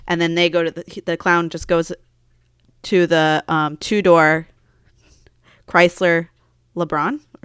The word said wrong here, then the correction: LeBaron: or is that how you say it? – I have no idea. – LeBaron, Chrysler LeBaron. When the, I'm going to have trouble say this car name.